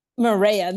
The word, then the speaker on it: Mireya: Mireya, there you go. Mireya.